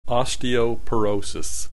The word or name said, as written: osteoporosis